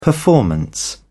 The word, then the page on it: performance: British English